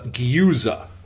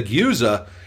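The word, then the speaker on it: Gueuze: I went to four different websites trying to figure out how to pronounce this. It's a Dutch word. And that is the one that was the most consistent. If Gyuza, if you slow it down.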